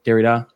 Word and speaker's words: Derrida: But I do think that probably another one of postmodernism's issues is that it is like Derrida. How do you say it? – Derrida.